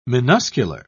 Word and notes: minuscular: British English

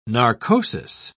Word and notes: narcosis: British English

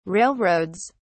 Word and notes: railroads: British English

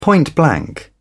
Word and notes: point-blank: British English

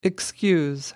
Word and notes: excuse: American English

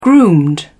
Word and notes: groomed: British English

